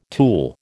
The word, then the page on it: tulle: American English